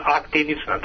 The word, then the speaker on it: aktinična: Još na početku, aktitična, ako dobro izgovaram, keratoza. – Aktinička je dobro? – Dobro, aktinična, dobro da zapamtim.